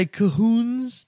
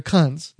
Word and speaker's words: Khund: Some people will try to say Kahoons, but it's spelled K-H-U-N-D. They're Kuns.